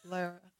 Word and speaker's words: Lara: Lara. – Yeah, like car or far or bar or tar.